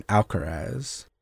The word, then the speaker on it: Alcaraz: All right, next one, Alcaraz.